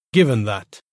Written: given that

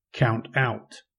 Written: count out

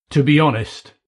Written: to be honest

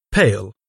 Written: pale

